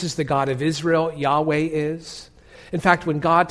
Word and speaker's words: Yahweh: In Hebrew, it's pronounced Yahweh. Yahweh.